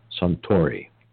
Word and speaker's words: Suntory: Ultimately, Mindy led the organizational negotiations for a $16 billion buyout of Beam by the large Japanese holding company, Suntory. Don't know if I said that right.